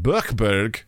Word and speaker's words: Buchberg: town of Buchberg late Wednesday. – Flawless pronunciation, by the way.